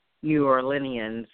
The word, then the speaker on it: New Orleanians: Well, you know, it's interesting because you know, you're speaking to the resilience of New Orleanians.